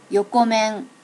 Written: yokomen

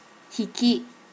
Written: hiki

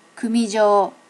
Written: kumijo

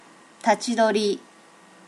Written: tachi dori

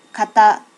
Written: kata

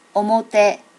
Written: omote

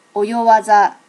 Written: oyo waza

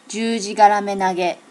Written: jujigaramenage

